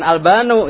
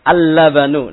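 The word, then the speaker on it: Al-labanu (اللبن): Labanun ketika ditambahkan dengan alif lam bacanya gimana? Bukan Albanu ya, atau Albani misalnya. Al-labanu. Nah, gitu ya.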